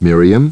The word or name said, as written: Miriam